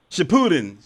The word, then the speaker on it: Shippuden: I can't pronounce it. Is it Shippuden? – Shippuden. Yes, Shippuden.